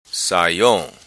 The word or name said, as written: Saiong